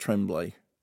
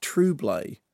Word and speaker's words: Tremblay: Somebody wrote to me on Twitter and said that Jacob Tremblay is not pronounced Tremblay, that apparently that he's pronounced Trublay.